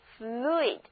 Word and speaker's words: fluid: 注意字母 u 和 i 要分别发音， u 要发长的 u，而 i 要发短促的 i，合起来就是 fluid， fluid。